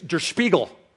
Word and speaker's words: Der Spiegel: He even taught me how to say the name of the magazine because I couldn't even do it. It was Der Spiegel, right, but with a lot more spit.